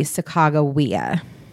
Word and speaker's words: Sacagawea: There's only a hard G. And so when you look at how Lewis and Clark recorded her name in their journals, and when you think about the letter and the pronunciations in the native languages, there is no soft G. So it would be Sacagawea.